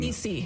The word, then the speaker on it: Niecy: We did talk about it last week because I mispronounced Niecy Nash's name. – Niecy. – Thank you.